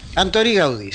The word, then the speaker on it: Antoni Gaudí: Antoni Gaudí, exactamente.